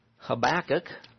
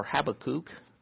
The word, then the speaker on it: Habakkuk: In Habakkuk, or Habakkuk, which I've heard it pronounced that way before, by people who are scholars in Hebrew.